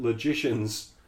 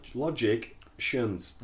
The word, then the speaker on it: logicians: So it says something like, but it would be a mistake to think that logicians are unfriendly. – Look how it's spelled. – Logic shuns.